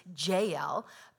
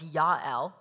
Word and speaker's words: Jael: Her name is Jael, but I'm going to pronounce it as Yael, which is the Hebrew pronunciation. And if you call her Jael, that's fine. That's what most people say.